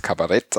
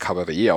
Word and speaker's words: Kabarett: Genau, und zwar in Deutschland wird es eher als Kabarett ausgesprochen, so wie es auch geschrieben wird. In Österreich und der Schweiz spricht man das Kabarett aus, wie es aus dem französischen Ursprung kommt.